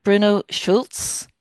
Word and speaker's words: Bruno Schulz: and tell me if I'm pronouncing his name right. Is it Bruno Schulz? – That's it. Yes.